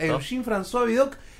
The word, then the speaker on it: Eugène François Vidocq: Y hoy nos traes la historia de Eugène François Vidocq, ¿lo dije bien? – Exacto. – Eugène François Vidocq, quien es, fue el primer detective de la historia, ¿es así, Fede? – Sí, bueno, también estaba...